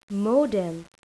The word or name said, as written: modem